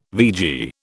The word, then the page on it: vegie: British English